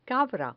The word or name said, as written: cabra